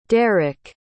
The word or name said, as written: derik